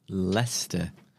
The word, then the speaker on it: Leicester: I'm going to have to DM him on and tell him how to Leicester. – Leicester. Just pronounce it like, it's like Uncle Fester. – Yeah. – With an L.